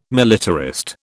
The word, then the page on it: militarist: British English